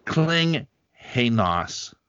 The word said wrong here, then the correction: Kinghanus: Am I missing something here? Lee Klinghanos. – King Hainos. Lee King Hainos. Oh, Lee King Hainos.